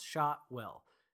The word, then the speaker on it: Shotwell: Shotwell. My name is spelled just like it sounds Shotwell.